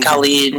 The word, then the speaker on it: Khalid: And uh, Khaled. – Mm-hmm. Uh, Lil- Khalid.